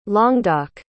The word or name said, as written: languedoc